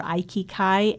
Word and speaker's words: Aikikai: And then my Aikido website is Crystal River Aikikai, AIKIKAI.